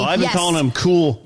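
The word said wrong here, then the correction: Kuiil: Quill is how you pronounce it.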